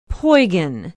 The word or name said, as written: poygan